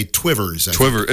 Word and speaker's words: Two Rivers: Yeah, the locals say Twivers.